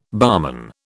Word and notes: barman: British English

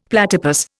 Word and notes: platypus: Indian

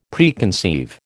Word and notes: Preconceive: American English

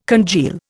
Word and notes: congeal: Indian

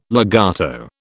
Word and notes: legato: British English

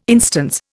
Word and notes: instance: Indian

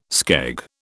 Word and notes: skeg: British English

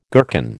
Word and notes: gherkin: American English

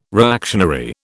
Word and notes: reactionary: British English

